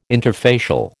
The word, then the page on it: interfacial: American English